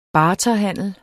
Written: barterhandel

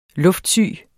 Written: luftsyg

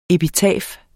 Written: epitafium

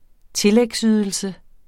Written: tillægsydelse

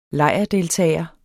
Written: lejrdeltager

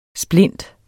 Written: SPLINTENS

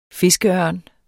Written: fiskeørn

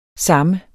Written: samme

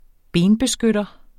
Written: benbeskytter

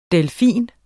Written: delfin

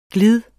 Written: glids

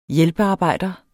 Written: hjælpearbejder

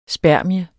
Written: spermie

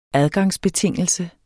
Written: adgangsbetingelse

